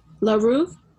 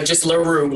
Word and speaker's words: LaRue: LaRue? Yeah, no, just LaRue, like Rue and then LaRue.